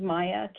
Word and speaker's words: Maija: This is Maya Kay from New York. – Maya Kay. Welcome. – It's spelled M-A-I-J-A.